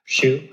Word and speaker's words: Hsu: And Stephanie Hsu, if I pronounce that right? – Hsu.